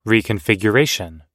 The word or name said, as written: reconfiguration